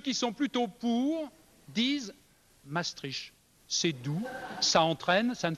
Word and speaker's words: Maastricht: Ça se prononce Maastricht.